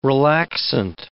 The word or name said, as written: relaxant